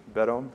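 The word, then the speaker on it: Beddome: written by a Baptist minister named Benjamin Bedome. I think I'm pronouncing his name right. One of you can correct me if I'm not. Is that right? Bedome? Yeah, I think it is.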